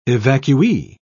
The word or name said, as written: evacuee